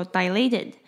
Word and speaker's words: dilated: 那这个字要注意的是呢，它前面的 di 的部分 di 它是发长音，所以这个字念作 dilated。